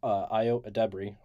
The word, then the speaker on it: Ayo Edebiri: Best supporting actress in a comedy series for Ayo Edebiri. Hope I'm saying that right. – Yes, you are.